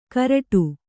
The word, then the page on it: ಕರಡು: Kannada